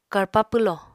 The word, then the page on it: karpa pwlò: Apatani